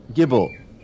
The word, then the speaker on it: Gibbel: What did you say his name was? – Gibble. G-I-B-B-E-L. Gibble.